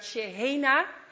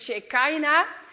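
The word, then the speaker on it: Shehena (שכינה): In het Hebreeuws spreek je het uit Shehena. En Mark zei in het Engels is het Shekinah.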